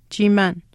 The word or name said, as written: chiimaan